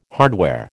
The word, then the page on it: hardware: American English